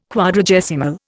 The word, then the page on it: Quadragesimal: Indian